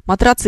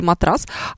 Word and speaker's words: матрас: Матрац или матрас. А, Шумай, смотрите, изначально он был матрац через букву «Ц», но поскольку матрац произносить неудобно, он потихонечку превратился в матрас.